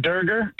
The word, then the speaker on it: Dorger: Well, we pronounce it Dorger, kind of like burger, but with a D.